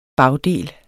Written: bagdel

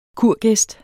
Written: kurgæst

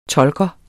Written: tolker

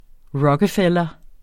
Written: rockefeller

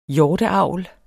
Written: hjorteavl

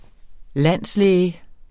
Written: landslæge

